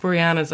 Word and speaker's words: Boreanaz: When I served David Boreanaz, is that how you pronounce Boreanaz. – It? Boreanaz.